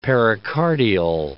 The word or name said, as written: pericardial